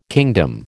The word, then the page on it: kingdom: American English